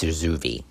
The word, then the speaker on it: Zurzuvae: Sometimes I say Zurzuvae, sometimes I say Zurzuvae. I think it's Zurzuvae. That's the right way to say it.